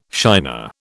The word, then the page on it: shiner: British English